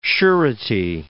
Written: surety

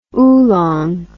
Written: oulong

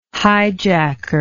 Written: hijacker

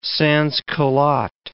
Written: sansculotte

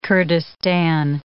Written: Kurdistan

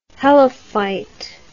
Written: helophyte

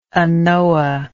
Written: anoa

